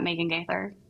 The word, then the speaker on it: Megan Gaither: So if you go in there, it's just like at Megan Gaither.